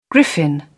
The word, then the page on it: griffin: British English